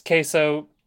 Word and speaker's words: keso: It was from a Filipino ice cream company, and it was ube and cheese, queso, K-E-S-O, which is just pronounced like it is in Spanish, but it's means cheese.